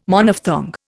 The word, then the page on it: Monophthong: Indian